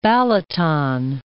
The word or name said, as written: Balaton, Lake